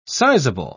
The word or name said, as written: sizable